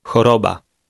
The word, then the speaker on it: choroba: Choroba. Choroba. – The CH combination sounds exactly like the Polish letter H.